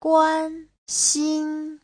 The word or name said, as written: guan1xin1